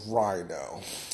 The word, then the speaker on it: Ryno: And the final gun that I want to talk to you about is the Rhino. And it's spelled R-Y-N-O, and it stands for Rip Ya New One.